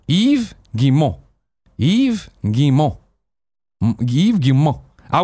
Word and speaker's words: Yves Guillemot: Yves Guillemot is pronounced Yves Guillemot, or alternatively, Guillemot. There's no S sound on Yves, no I sound, T sound, or nasal N sound in Guillemot.